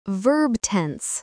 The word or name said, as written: verb tense